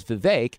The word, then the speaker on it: Vivek: But I also thought that you were Vivek and he was Vivek, but your name is pronounced correctly, Vivek, like cake, right? – That's correct.